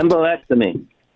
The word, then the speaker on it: Embolectomy: Embolectomy.